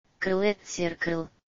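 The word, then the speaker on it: Kholat Syakhl: Okay, here's how it's actually pronounced. – Klatsikl. – Which is a Russian translation of the Mansi name.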